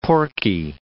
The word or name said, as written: porky